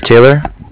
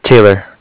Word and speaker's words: Taylor: So, my name is Taylor. – Your name is what now? – Taylor. – T-A-Y-L-O-R? – Correct.